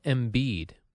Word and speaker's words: Embiid: Joel Embiid.